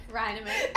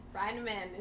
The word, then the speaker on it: Reinemann: How do you say it? – Reinemann.